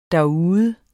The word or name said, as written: derude